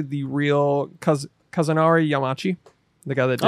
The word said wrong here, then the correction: Kazunori Yamauchi: How do you say it? – Where? Let me see it. – It's right here. Highlighted for you. – Kazunori Yamauchi. – Excellent. Thank you.